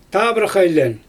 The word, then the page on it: taabrax ajlJɛn: Xyzyl